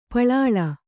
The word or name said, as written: ಪಲ್ಲಿಲಿ